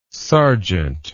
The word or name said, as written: serjeant